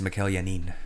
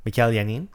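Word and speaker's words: Mikel Janín: I'm pretty sure it's Mikael Hanin. – Hanin? – Yeah. – Mikael Hanin.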